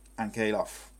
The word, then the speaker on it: Ankalaev: The Russian, I can't say his name, but Ankalaev.